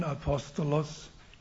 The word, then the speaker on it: Apostolos: Apostel ist ja ein griechisches Wort, deutsch ausgesprochen. Genau im Griechischen Apostolos.